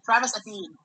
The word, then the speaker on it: Etienne: But even prior to that, they came in, drafted a running back, then swore, well, we're going to use Etienne to, how you say it? – Travis Etienne.